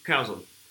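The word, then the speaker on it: Chazown: En, anglais, c'est, ça s'écrit Chazon, mais ça se prononce Carzone.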